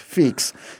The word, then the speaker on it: feex: F-E-E-X, feeks.